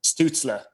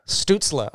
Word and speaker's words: Stützle: Am I pronouncing that right? Tim Stützle? – Stützle. – Stützle. Okay, well, getting a German lesson here.